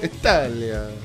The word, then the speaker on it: Italia: Italia. Is that how you say it? – Italia.